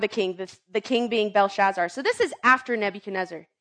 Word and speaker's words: Belshazzar: Belshazzar, there it is. It was close.